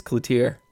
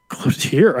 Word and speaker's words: Cloutier: Watts said he was correct. It's Cloutier. – Cloutier. I like that a lot better. – Right. He said Brayden at some point in his soccer career, people started pronouncing it the French way.